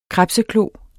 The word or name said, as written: krebseklo